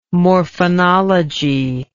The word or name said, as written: morphonology